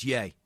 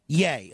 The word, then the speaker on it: Ye: Is the proper pronunciation of his new name Ye or Ye? – It's Ye. – Yay. Okay, thank you.